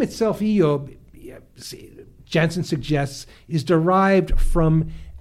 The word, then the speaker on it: Job: But in his book, he writes that Job, the name Job, in Hebrew is would be pronounced Eob.